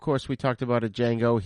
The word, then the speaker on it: Django: He's been in Enter the Ninja, Die Hard 2, Django Unchained, which I remember seeing him when I saw Django Unchained in the theater, and he was sitting at the bar with Django. I was like, oh, my God, that's Franco Nero. Oh, shit. It's the D is silent.